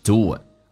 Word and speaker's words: do it: 那么 do 跟 it 有一个连音，元跟元之间的连音不是加我就是加也，那这个加个我叫 do it，不要读那么明显 do it，而且 t 不发音尾音，气流阻塞一下，因为前面是元音，叫 do it。